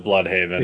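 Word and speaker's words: Blüdhaven: But yeah, the B-L-U-D with the umlauts is over the Haven. – I did not see the umlauts. – Yeah, alright. – It was there. – They pronounce it Bloodhaven. – Yes, they do. – In the show, because they are not German. They are Americans.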